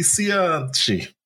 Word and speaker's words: Cianci: It actually has on his Wikipedia page both pronunciation and Italian pronunciation. – You have to use the Italian pronunciation. – Buddy Cianci.